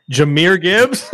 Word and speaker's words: Jahmyr Gibbs: Jahmyr Gibbs.